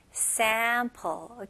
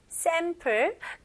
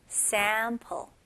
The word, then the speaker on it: sample: 영어로는 sample, okay? 우리나라 발음 sample, 근데 더 입을 벌리셔야 돼요. sample, sample, okay? 강세는 앞부분에 들어갑니다.